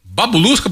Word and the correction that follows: bagulusca: professor, a origem é essa? – É com G de O, bagulusca.